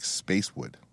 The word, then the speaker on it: Spicewood: But the way they pronounce it, it sounds like Spacewood.